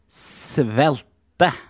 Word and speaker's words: Svelte: Svelte. – Svelte. – Mm. Tror du det heter uttales egentlig svelt? – Stemmer, svelte.